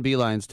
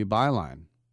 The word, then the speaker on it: Beilein: And so this guy, by the way, if he pronounced it the correct German way, it would be Beilein. Why he says Beilein, I don't know. It's E-I-E-I twice. Like E-I-E-I-O.